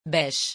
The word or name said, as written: bêche